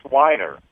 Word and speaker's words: Weiner: Well, not Wiener, it's Weiner. I, okay.